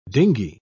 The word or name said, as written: dinghy